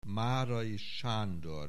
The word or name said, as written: Márai, Sándor